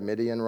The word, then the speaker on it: Midian: And if you're a Bible scholar and very smart, you would have recognized that Midian rhymes with Gideon, and you would have thought maybe he's talking about Gideon, and he is.